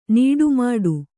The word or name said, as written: ನಡೆಮುಡಿ